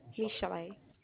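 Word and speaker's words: Michel'le: Michelle. – Can you spell that for me, please? – M-I-C-H-E-L apostrophe L-E.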